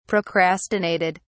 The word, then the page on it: procrastinated: British English